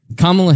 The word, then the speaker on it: Kamala: Kamala Harris. I'm pronouncing her name right. – Yeah, that's right.